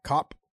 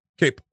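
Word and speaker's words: Kape: How do you say his last name? Cop? – Cape. – Cape? – Okay. Yeah, Manel Cape.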